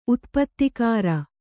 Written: ಉತ್ಪತ್ತಿಕಾರ

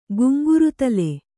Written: ಗುಂಗುರುದಲೆ

